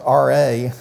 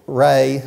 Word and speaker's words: Ra: Sometimes it's spelled R-E and sometimes R-A. I pronounce it Ray.